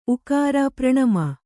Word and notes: ಪ್ರಣಾಮ: praṇāma

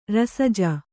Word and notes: ರಸಜ: kannada

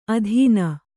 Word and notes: ಅಧೀನೆ: adhīne

